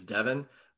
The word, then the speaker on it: Devon: Yeah, I always feel bad uh, whenever I fuck up somebody's name because my name is Devin. Um, obviously, I just go by Dev, but um, it's spelled D E V O N, so everybody always assumes Devon.